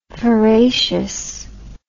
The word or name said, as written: feracious